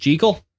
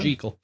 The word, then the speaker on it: Jekyll: You know, it's supposed to be pronounced Jekyll.